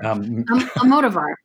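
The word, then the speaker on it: Almodóvar: Almodovar. – Almodovar.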